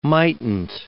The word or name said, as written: mightn't